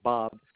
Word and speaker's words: Bob: It's my first and last name, starting with Bob.